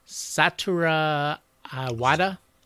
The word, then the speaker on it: Satoru Iwata: So you saw it was after Switch came out, and the former president, let's hope I don't butcher his name here, Satoru Iwata.